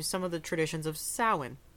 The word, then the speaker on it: Samhain: So, I looked it up and it's pronounced Samhain. Yes. Or like Samhain.